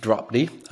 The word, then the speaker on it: Drobny: It's Steve Drobny, the founder of Clock Tower Group. Steve, it's an immense pleasure to host you. – Thank you. And the pronunciation is Drobny with a strong O.